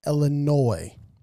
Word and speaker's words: Illinois: And it's pronounced Illinois. The S is silent.